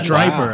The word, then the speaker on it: Stryper: Striper. Wow. Okay, that's why I couldn't find it, because it's spelled S-T-R-Y-P-E-R. – Yeah, it's not like the fish.